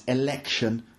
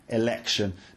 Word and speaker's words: election: Note, if you're perhaps a Japanese speaker, that word can be a little bit tricky to pronounce, election, right? Because you have to get the L sound, election.